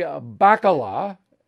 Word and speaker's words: Bacala: And then I contrast you to Bobby Bacala, am I saying that correctly? – Yes.